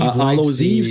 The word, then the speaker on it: All Hallows Eve: Puis, honnêtement, dans l'anthologie, le, je l'ai regardé, c'est All Allows All Hallows Eve. – All Hallows Eve, c'est ça qu'on prononce.